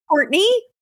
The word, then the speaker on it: Courtenay: So it is Courtenay, but it is spelled Courtenay. So Courtenay, Turner, Turner.